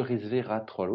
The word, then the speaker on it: resvératrol: Je prononce toujours mal en plus, c'est ridicule, mais qui est un antioxydant, le resvératrol. Oui, maintenant je le prononce bien.